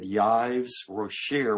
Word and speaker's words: Yves Rocher: I asked her how to pronounce it. I'm horrible with French, and I think she said Yves Rocher, which I believe they have some of those here.